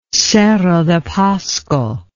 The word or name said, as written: cerro de pasco